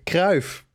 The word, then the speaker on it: Cruyff: Yeah, Cruyff.